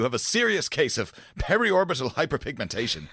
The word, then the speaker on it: periorbital hyperpigmentation: Okay, so the clinical term for dark circles is, as always, I'm going to definitely pronounce this wrong, but I'm going to give it my best attempt. Periorbital hyperpigmentation? – Periorbital hyperpigmentation. – Periorbital hyperpigmentation. – There you go.